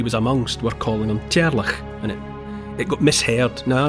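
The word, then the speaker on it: Teàrlach: It's a mishearing of the Gaelic form of Charles, which is I apologise unreservedly for what's going to be my mispronunciation, but in Gaelic speakers' mouths, Charles is rendered into a name that sounds a bit like Cherlach.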